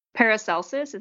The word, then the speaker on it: Paracelsus: There's a quote from Paracelsus. Is that how you say it? – Sure.